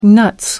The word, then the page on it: nuts: American English